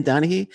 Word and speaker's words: Donaghy: Is that how you pronounce it? Tim Tim Donaghy. – Yeah.